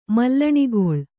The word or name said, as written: ಮಲ್ಲಣಿಗೊಳ್